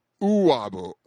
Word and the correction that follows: Uwabo: Uwabo. – Uwabo. – U-W-A-B-O.